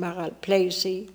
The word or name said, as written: Maralpleicie